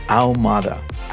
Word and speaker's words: Ahumada: And by the way, my last name is pronounced Ahumada. How Like you got just hurt in the arm. How Mada. It's not Armada. It's not Humdada. It's Ahumada. Easy.